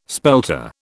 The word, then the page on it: spelter: British English